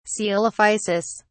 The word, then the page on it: coelophysis: British English